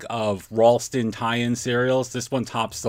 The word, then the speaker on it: Ralston: I definitely went too far with the pronunciation last show. Ralston, you got to have a little bit of a W in there.